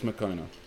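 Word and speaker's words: Moccona: Is it Makona? Yeah, it's Makona. I was like, am I pronouncing that right? I feel like I put a different letter in the middle of that isn't right. But it's Makona.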